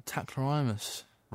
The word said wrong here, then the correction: Tacrolimus: Tacrolimus, is I'm saying that right? – Yeah. Tacrolimus, yeah.